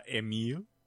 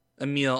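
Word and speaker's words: Émile: Uh, Emily Borel. Sure. Uh, Emile. Emile. Oh, the.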